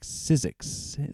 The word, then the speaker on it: SYZYGYX: Before that, the latest from the DC-based act, SYZYGYX, Sizzix is how it's pronounced. Deeper is the song.